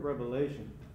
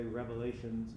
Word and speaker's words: Revelation: And again, we've mentioned that the book of Revelation, and that's with an N at the end, okay, Revelation, not an S. You'll hear people mispronounce it and say Revelations. No, it's one revelation that has a tremendous amount of components.